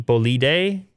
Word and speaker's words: bolide: B-O-L-I-D-E. Bolide? Bolide? – Oh. – I don't know. Okay. How do you want to pronounce that? B-O-L-I-D-E. – Yeah.